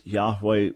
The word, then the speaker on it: Yahweh: The best that we can reconstruct is that the name originally would have been pronounced Yahweh, which would have meant something like he who brings things into existence, he who makes things happen.